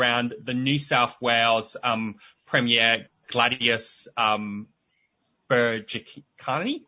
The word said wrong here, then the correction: Berejiklian: Oh, how do you pronounce her last name? – Berejiklian. – Berejiklian.